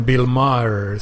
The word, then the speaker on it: Bill Maher: Och förresten, förresten, Bill Maher som jag nu kanske lyckades uttala efternamnet på. Ja, det lät okay.